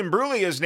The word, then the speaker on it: Imbruglia: Natalie Imbruglia's name is Imbruglia, not Imbruglia.